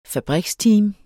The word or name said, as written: fabriksteam